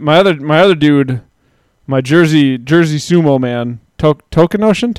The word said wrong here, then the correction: Tochinoshin: Token Ocean. How do you say his name? – T- uh, Tochinoshin.